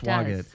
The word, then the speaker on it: Swagat: How do you pronounce it? Swagat. It looks like Swagat, but it's Swagat.